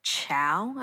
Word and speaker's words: Chao: I also like Chao, I believe is how you pronounce it, C-H-A-O.